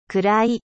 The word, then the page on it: sombre: French